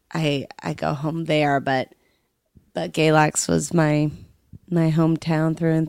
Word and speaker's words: Galax: So is from Galax. That's how you pronounce it, right? – Mm-hmm.